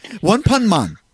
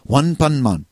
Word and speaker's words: One Punch Man: One Pun Man. By the way, that's how they pronounce it in Japan. One Pun Man. So if you hear somebody say it that way, that's what they're really saying. I just Saitama is such a funny guy.